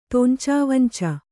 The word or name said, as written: ತೊಂಚಾವಂಚ